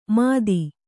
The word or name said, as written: ಮಥಿ